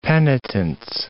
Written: penitence

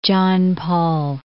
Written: John Paul

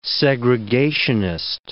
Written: segregationist

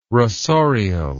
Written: rasorial

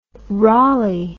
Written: rolley

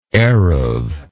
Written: eruv

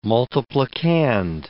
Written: multiplicand

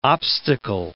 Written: obstacle

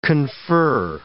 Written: confer

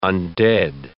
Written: undead

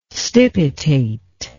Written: stipitate

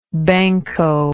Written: banco

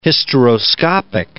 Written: hysteroscopic

